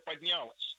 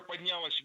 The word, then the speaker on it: поднялась: не просто возможно за горизонтом там поднимется, она уже поднялась. Она уже поднялась везде. – Не поднялась, а поднялась. И здесь все та же картина. Ударение падает на последний слог, поближе к части «с».